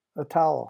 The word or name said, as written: a towel